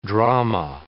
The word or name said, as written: drama